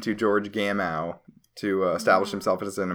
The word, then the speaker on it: Gamow: I always thought his name was Gamow. Yeah, I think he's from some country where the W is pronounced with a V.